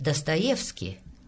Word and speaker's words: Dostoyevsky: Entonces decimos Tolstoy y Dostoyevsky, pero en realidad, ¿cómo se pronuncian? – Pues mira, Tolstoy es Tolstoy, uh-huh. Dostoyevsky es Dostoyevsky, Gogol es Gogol. Y en una